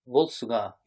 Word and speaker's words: Volsunga: Okay, so this story comes from chapters 6 to 8 of the Volsunga saga. Is that saying it right? – Volsunga, yeah, Volsunga.